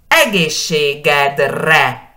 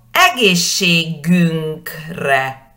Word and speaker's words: egészségedre: Y si cambio el posesivo, digo egészségünkre. Ese cambio es porque ahora digo a nuestra salud. Egészségünkre, egészségedre, tu salud egészségünkre, nuestra salud, ¿no? A nuestra salud. Y siempre enfatizando la primera sílaba.